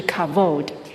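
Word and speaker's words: kavod (כבוד): 荣耀这个字 kabod 是希伯来文，读法是 kavod，在希伯来文的 v 跟 b 是同一个字母，写的时候是写 b kavod，但是发音读的这个字呢是 kavod v 音。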